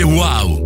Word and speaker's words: wow: Si scrive UAU, si legge wow.